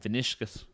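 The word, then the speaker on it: Vinícius: And then Real Madrid have Vinicius.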